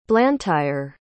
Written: blantyre

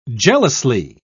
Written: jealously

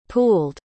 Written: pooled

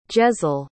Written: jezel

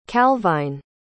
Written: calvine

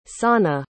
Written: sanne

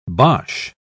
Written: boche

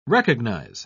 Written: recognize

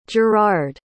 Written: girard

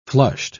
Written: flushed

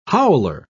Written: howler

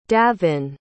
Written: davin

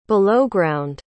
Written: belowground

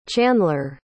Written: chanler

